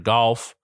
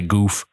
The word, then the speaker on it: Goff: I'm saying it wrong. Jared Goff, I about to say goof, but and I also I'm trying.